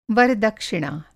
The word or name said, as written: वर-दक्षिणा